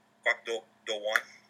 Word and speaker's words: Kwak Do-won: That's a very haunting image, and I think the guy who plays Jong-gu, is it Do-won Kwak? Am I saying that right? – Kwak Do-won.